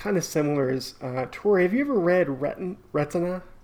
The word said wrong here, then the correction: Remina: Remina? – Sorry, I'm saying it wrong.